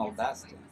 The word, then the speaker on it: Audacity: Chama Audacity? – É, a pronúncia é Audacity.